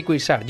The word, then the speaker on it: Cuixart: La jueza Carmen Lamela envía a prisión a Jordi Sánchez y Jordi Cuixart. Jordi Sánchez de la Asamblea Nacional Catalana y Jordi... Perdón, sí, lo he dicho bien, ¿no? Sí. Y Jordi Cuixart del Òmnium Cultural.